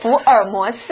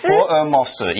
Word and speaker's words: 福尔摩斯: Yes, definitely Sherlock Holmes, or what, Formos, is that right? Is that how you say it? – 福尔摩斯。 – 福尔摩斯, yes.